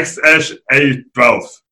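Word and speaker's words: X Æ A-12: Uh, Ludku, je to uh, X, uh, pak je něco, co většinou se vyslovuje jako Ash, ale je to takový AE, E, takže X, Ash a pak A12. Takže kdyby si to chtěl říct nějak tak jako anglicky, tak asi X, Ash, A12.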